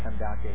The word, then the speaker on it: Kandake: If you actually read that in the original language, it's spelled K-A-N-D-A-K-E. Okay, Kandake. I think Candace sounds better, but it's actually Kandake.